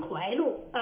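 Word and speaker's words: 获鹿: 但是呢，这个霍路县啊，当地人呢读成怀路啊，这个霍路县当时是存在的，而且这个是一个历史蛮长的一个县城。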